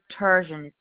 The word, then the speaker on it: Turgeon: Last name is Turgeon. T as in Thomas. U-R-G as in George, E-O-N.